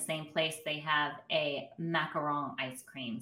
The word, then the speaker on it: macaron: I'm a huge fan of macaron. If they're good, they're good. – I'm a huge fan of you pronouncing it that way.